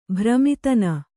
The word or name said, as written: ಭ್ರಮಿತನ